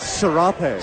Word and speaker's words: serape: Serape.